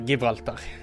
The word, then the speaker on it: Gibraltar: Jeg uttaler det på spansk, som sikkert er Gibraltar.